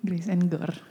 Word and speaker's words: Grace and Gore: Jadi dia adalah seorang musisi dan juga music engineer dan gak lama ini juga baru ngeluarin debut singlenya yang bernama Grace Orgor, benar gak sih cara bacanya gitu? Grace and Gore.